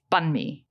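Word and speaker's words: bánh mì: Banh mi. – Yeah, which is wrong. – How do you say it? – Because ban is not elongated. – It's banh mi.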